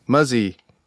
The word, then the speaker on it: Muzzy: Wait, how do you say his name? – Muzzy. – Muzzy? – Muzzy.